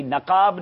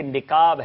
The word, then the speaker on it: نقاب: جی جی نقاب نہیں ہے بلکہ نقاب ہے, سبحان اللہ.